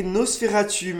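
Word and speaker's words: NOS4A2: avec la série Nosferatu, mais alors que c'est n'écrit pas Nosferatu, s'écrit NOS4 a2.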